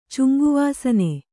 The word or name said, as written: ಚುಂಗುವಾಸನೆ